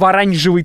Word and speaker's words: оранжевый: И обычно его красят в оранжевый цвет. Кстати, правильно говорить оранжевый, это ужасно, но так правильно.